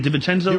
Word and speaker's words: DiVincenzo: Because, look, what we did we replaced DiVincenzo. How you say it? DiVincenzo? – You're right, yeah.